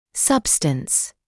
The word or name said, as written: substance